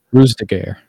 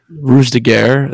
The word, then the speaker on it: Ruse de Guerre: Ruse de Guerre. – Ruse de Guerre, the newest Guy Richie film with Jason Statham that got delayed for a billion years.